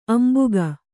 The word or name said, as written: ಅಂಬಿಕ್ಕು